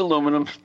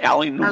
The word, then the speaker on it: aluminum: Just like aluminum is aluminum. It's not aluminium.